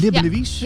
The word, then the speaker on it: Libbenewiis: Dat doe ik met eh, leerkracht van de Friese School Libbenewies. Zeg ik dat goed eigenlijk? – Libbenewies? Ja, ja, helemaal keurig.